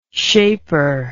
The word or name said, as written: shaper